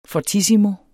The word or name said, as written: fortissimo